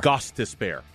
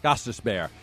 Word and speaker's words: Gostisbehere: Gostisbehere. – Gostisbehere.